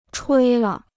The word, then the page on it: 吹了: chuī le